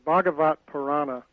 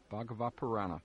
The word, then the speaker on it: Bhagavata Purana: Am I pronouncing this correctly? If not, I can... – It's easiest to drop the A at the end. – Oh. – Because you can either pronounce it or not, but Bhagavat Purana.